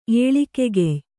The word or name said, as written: ಏಳಿಕೆಗೆಯ್